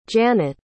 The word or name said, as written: jannet